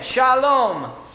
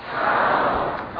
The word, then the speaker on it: Shalom: No ah in Hebrew. No I, no short I in Hebrew. Only E. Only E. Tel Aviv. Not Tel Aviv. Tel Aviv. Once again, Shalom. – Shalom.